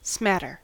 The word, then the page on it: smatter: American English